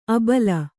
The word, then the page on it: ಅಬಳ: abaḷa